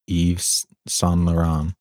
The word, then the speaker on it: Yves Saint Laurent: a biopic of influential French fashion designer Yves Saint Laurent or Saint Laurent. How bad am I mispronouncing that? – No, I think you did pretty good.